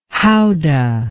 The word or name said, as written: houdah